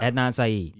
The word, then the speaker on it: Adnan Syed: Oh, Adnan Syed? – Is that how you say his name? – Yeah. – Adnan Syed.